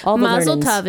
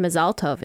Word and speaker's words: Mazel Tov: Mazel Tov is Yiddish and Mazal Tov is Hebrew.